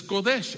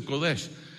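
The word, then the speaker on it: Kodesh (קודש): Que nuestras letras es Kodesh, así se pronuncia, Kodesh.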